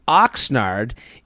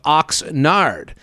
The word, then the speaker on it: Oxnard: Oxnard isn't actually pronounced Oxnard, but it's more of an more of Oxnard. Think the Nard dog from The Office.